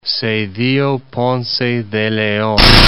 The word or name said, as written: Zedillo Ponce de Leon